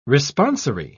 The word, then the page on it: responsory: British English